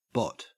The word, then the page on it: bot: Australian English